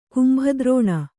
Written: ಕುಂಭದ್ರೋಣ

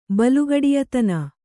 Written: ಬಲುಗಡಿಯತನ

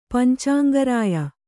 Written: ಪಂಚಾಂಗರಾಯ

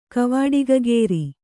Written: ಕವಾಡಿಗಗೇರಿ

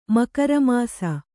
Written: ಮಕರಮಾಸ

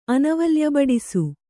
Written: ಅನವಲ್ಯಬಡಿಸು

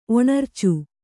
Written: ಒಣರ್ಚು

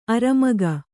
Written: ಅಱಮಗ

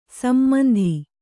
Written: ಸಮಂತು

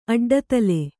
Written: ಅಡ್ಡದೊಲೆ

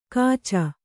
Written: ಕಾಚ

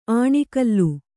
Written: ಆಣಿಗಲ್ಲು

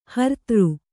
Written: ಹರ್ತಾರ

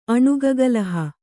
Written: ಅಣುಗಗಲಹ

